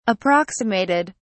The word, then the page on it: approximated: British English